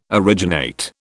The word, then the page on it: originate: British English